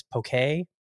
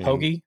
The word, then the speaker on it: poke: I saw on an article, they say in Hawaii it's poke. You call it pokey? – I call it pokey. – Pokey, okay. Had my first pokey experience.